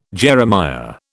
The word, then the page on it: Jeremiah: British English